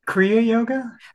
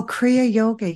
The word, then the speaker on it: Kriya Yoga: then you learned with Chinese masters and later became a Kaya Yoga. Did I say that right? Kriya Yoga? Oh, Kriya Yoga. Yeah.